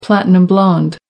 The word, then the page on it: platinum-blonde: American English